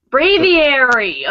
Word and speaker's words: Braviary: Braviary, okay. – But it looks like it doesn't even spell out the word brave in the beginning, which is confusing to me. – Braviary. – B-R-A-V, brave.